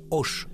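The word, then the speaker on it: Hoche: Ну, да, у Да, они, кстати, говорят не Хош могут, а Ош. – Уш, уш, уш, да. Первое Аш не читается, да.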